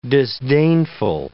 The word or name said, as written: disdainful